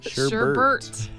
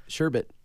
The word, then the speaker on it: sherbet: Sherbert. I'm on code. It's Sherbet.